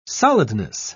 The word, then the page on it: solidness: British English